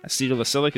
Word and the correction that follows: acetylsalicylic: is that how you say it? I think that's... – Yeah, that sounds great. – Acetylsalicylic. Sorry. Acetylsalicylic acid.